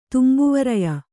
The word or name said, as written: ತುಂಬುವರಯ